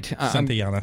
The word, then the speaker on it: Santayana: So, I mean, I must pronounce that wrong, I'm sure. – No, that's right. – Okay, great. – Santayana.